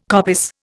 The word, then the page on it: coppice: Indian